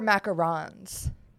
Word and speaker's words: macaron: But I think they were macarons. The sandwich ones. Macaroons are like the coconut thingies. – Do you pronounce it differently? I had no idea. Is that true? – One has one O and the other has two O's.